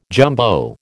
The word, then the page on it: jumbo: American English